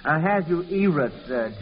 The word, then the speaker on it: Ahasuerus: Ahasuerus. – I think it's pronounced Ahasuerus.